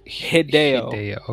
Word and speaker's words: Hideo: How do you pronounce the first name again? – Hideo.